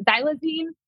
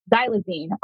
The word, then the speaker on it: xylazine: Is it xylazine? Am I saying that right? – As far as I know. Yeah.